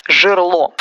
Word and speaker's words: жерло: Запомните эти слова, чтобы не допускать досадных ошибок жерло. В это сложно поверить, но словари велят нам выделять интонационно именно последний слог. Во множественном числе ударение переезжает на букву «е». Это касается и жерла вулкана, и части оружия.